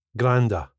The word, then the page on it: granda: スコットランド英語